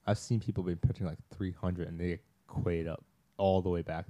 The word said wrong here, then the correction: queued: How do you pronounce that word? – It's pronounced Q. – Queued up. I'm sorry. Queued up. – And by the way, that's the word Q-U-E-U-E.